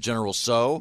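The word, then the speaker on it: General Tso: You just pronounce it general so? – Yeah, yeah, yeah. – Oh, I thought you were supposed to hit the t. – No. Not okay. necessary. – I'll say sorry. – No, the T is silent like in pterodactyl.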